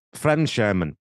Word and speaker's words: Fremdschämen: I became overwhelmed with, how do you say that? – I think it's Fremdscham. Fremdschämen. It's a German word.